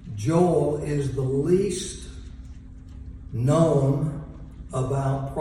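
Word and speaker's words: Joel (יואל): Today we're looking at Joel, or as it is pronounced in the Hebrew, Joel, El being the name of God.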